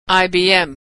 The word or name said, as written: IBM